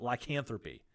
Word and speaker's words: lycanthropy: And that is the topic of and correct me if I'm saying this wrong, but lycanthropy. That's right. Is that right? That's right.